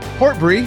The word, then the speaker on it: Portbury: And I have the director of the film, Kyle Portbury, here with us, direct from Australia by way of Tampa.